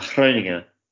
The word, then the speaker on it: Groningen: I'm in Groningen, as the Dutch would pronounce it.